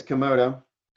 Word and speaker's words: Komodo: And I always say it wrong. Komodo? – I think it's Komodo.